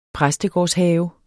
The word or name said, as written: præstegårdshave